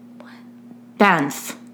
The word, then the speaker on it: Banff: What? Banff. Yeah, that's what we're saying. We're just saying it with an "and" instead of an "on."